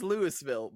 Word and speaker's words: Louisville: It's Louisville. It reads Louisville, but it's pronounced Louisville.